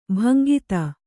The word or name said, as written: ಭಂಗಿತೆ